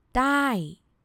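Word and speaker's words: Die (ได้): The first word, the word that you already know, the word die. Well, it's supposed to be a short vowel sound, but Thai people pronounce it with a long vowel sound, with a falling tone. Die.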